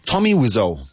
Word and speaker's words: Tommy Wiseau: Lo Ciber says, Tommy Wiseau. I don't know how to pronounce this. – Tommy Wiseau.